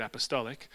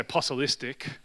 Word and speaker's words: apostolic: And suddenly now, thankfully 6 months before I met Tim Jack, I learned how to say the word apostolic. So you always want to say apostolistic.